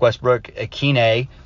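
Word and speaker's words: Westbrook-Ikhine: But I mean, if you watch the highlights from yesterday, I mean, Nick Westbrook Ikhine, which is apparently how you say his name now, or I mean, apparently that's how you were always supposed to say his name, but he corrected people the other day.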